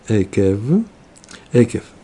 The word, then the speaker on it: Экев: У нас сегодня скромность, недельный раздел Экев, такое ударение.